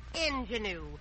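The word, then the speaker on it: ingénue: Mr. Haley, the word is not ingenue. It's a French word, and it's pronounced ingenue.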